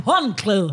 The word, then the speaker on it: håndklæde: Ej, jeg elsker That's not how you pronounce håndklæde. – Hong, more like håndklæde, huh?